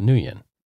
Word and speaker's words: Nguyen: It's Nguyen, and it's pronounced Nguyen.